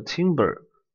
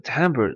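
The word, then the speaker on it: timbre: 哎，下面一个是 timber，哎，timber 它是音色，哎，千万不要把它读错，读成了 timber，哎，timber 它是木材，这个 timber 它是音色。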